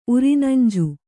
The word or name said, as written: ಉರಿನಂಜು